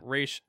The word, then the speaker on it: Ra's: It's just like it, it's Raish, it's Raish.